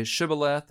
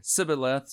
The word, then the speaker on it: Shibboleth: If he said no, then they would say to him, say Shibboleth, and he would say Sibboleth. So apparently their accent, the way they spoke, they couldn't pronounce that particular word. This is kind of how they identified them. For he could not pronounce it right.